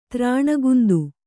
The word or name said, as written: ಧರಣೀಕಂದ